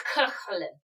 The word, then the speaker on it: Cú Chulainn: She trained the greatest hero of Irish legend, Cú Chulainn, with the KH in Scottish lock. – Oh, so kuh. – Kuh. – So pronounce it again with a kuh. – Kuhlin.